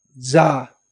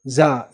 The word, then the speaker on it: Tsar: No, I'm asking how in Russian pronunciation, how do you say that? Tsar. Tsar, Tsar, yeah. Just Tsar, straightened, yeah.